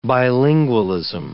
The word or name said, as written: bilingualism